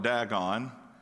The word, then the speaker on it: Dagon: Now, the pronunciation of this is Dagon. Where I'm from, we call it Dagon. So if I interchange those, just keep that in mind.